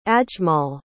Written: ajmal

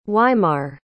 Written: Weimar

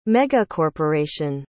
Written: megacorporation

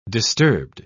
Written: disturbed

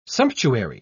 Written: sumptuary

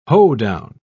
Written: hoedown

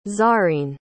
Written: zarreen

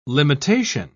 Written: limitation

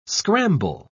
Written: scramble

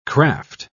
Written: craft

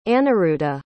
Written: Anirudhha

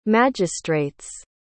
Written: magistrates

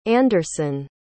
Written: anderson

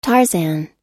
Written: tarzan

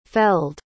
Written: felled

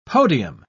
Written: podium

